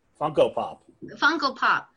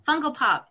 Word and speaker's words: Funko Pop: Funko Pop. – Funko Pop.